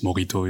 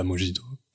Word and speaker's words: mojito: ça se prononce morito et pas mojito